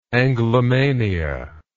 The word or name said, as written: anglomaniacal